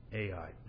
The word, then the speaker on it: Ai: between Bethel and Ai." This is pronounced Ai, but it's got an H in front of it.